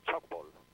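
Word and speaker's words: Tchoukball: Bueno, a ver, esto se pronuncia algo así como chalkball, ¿vale?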